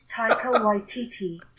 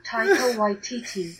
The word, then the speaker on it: Taika Waititi: Taika Waititi. Taika Waititi. Taika Waititi. – Don't forget your public service announcement from this episode is to proper pronunciation of names, such as... – Taika Waititi.